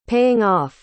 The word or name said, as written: paying off